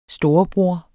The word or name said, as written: storebror